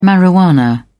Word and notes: marijuana: British English

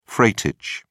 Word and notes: freightage: British English

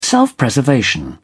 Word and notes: self-preservation: British English

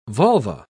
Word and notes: volva: British English

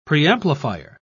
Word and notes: preamplifier: British English

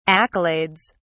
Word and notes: accolades: British English

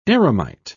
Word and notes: eremite: British English

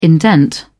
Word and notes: indent: British English

